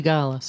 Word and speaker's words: Sigalas: Is Sigalas the way to pronounce it? – Sigalas. – Sigalas.